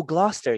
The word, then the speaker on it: Gloucester: How do you say it? Gloucester? – What state? Where are we at? – I'm right up the street from you. I'm in Massachusetts. – Oh, Gloucester. Yeah, hell yeah.